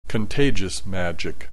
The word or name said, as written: contagious magic